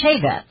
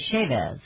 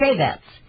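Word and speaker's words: Chaffetz: But it's pronounced Chavetz. And we always say Chavez. But it's Chavetz.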